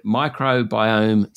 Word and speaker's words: microbiome: I said microbiome science, and I was hoping that I had pronounced that correctly. If I'm not sure how to pronounce it, I certainly don't quite understand what it is. Please explain. – I think you did a great job pronouncing that.